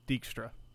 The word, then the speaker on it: Dijkstra: D-I-J-K-S-T-R-A. – Dijkstra. – Sure. Dijkstra? – Dijkstra.